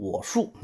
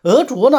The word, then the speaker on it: 莪术: 下一个药物呢是鹅竹，这两个字都蛮难认的，是吧？第一个字要读鹅啊，第二个呢就跟白竹的竹一样，要读竹，不要读树啊，你不要读成我树，那就错了。鹅竹呢，它是姜科的多年生草本植物，鹅竹、郁金或者是广西鹅竹的根茎啊，请注意，它也是根茎啊。